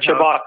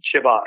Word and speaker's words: Chibok: And the remaining, is it Chibok girls? – The Chibok girls, yes. Yep, Chibok.